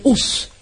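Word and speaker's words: hús: nous, c'est us, H-2-U-S. En islandais, c'est H-U avec un accent aigu S. Et le U avec un accent aigu se prononce OU. Donc ça fait us. Enus, et nous, c'est us.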